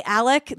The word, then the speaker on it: Alex: When you do this on TCO, so it's Alec Murdaugh. It's written Alex Murdaugh, but it's Alec Murdaugh. Like E-L-I-C-K, Alec Murdaugh is how you're supposed to pronounce this guy's name.